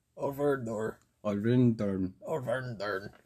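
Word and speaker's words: Örvar-Odds: Did I pronounce that right? – It's pretty close. – Pretty close? Okay, what's the... – Orvar Odar. There you go.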